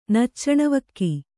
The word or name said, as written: ನಚ್ಚಣವಕ್ಕಿ